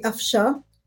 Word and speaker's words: Afsha: So the N is actually silent in my name. So it's actually Afsha is how you pronounce it.